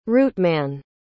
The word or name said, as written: routeman